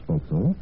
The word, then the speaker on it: forecastle: In the sometimes odd way of words, the term forecastle, which we pronounce forecastle, has survived, while aftercastle has gone into virtual oblivion.